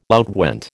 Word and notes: outwent: American English

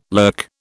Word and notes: lurk: British English

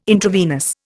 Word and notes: intravenous: Indian

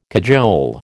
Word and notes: cajole: American English

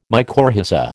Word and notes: mycorrhiza: American English

